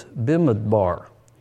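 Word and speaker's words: bimidbar (בְּמִדְבַּר): In the Hebrew, the Texas pronunciation is bimidbar, and that means in the wilderness.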